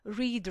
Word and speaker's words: read: Read, read, read, read, read, read. Te wszystkie trzy formy pisze się tak samo, a wymawia się je różnie.